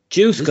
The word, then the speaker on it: JYSK: But that one, yeah, we have a new, how do you pronounce it? Is it Jesk? J-Y-S-K? – Jusk, I say, J-Y-S-K.ie.